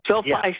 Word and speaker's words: SoFi: It's SoFi Stadium? – SoFi Stadium.